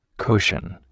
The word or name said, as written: cushion